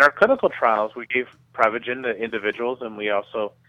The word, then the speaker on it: Prevagen: So remind us, what exactly is pre-vegan? – Well, technically it's pronounced Prevagen,